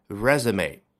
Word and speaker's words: resume: Kim Kardashian has added billionaire to her resume. 上来就说的非常的干脆。金卡戴珊已经在她的简历上加上了 billionaire, 也就是亿万富翁这一条。说一说 resume 啊，这个发音就让你想起来应该是从法语当中借过来的，果然如此嘛。再来一遍，它的发音比较的奇怪， resume。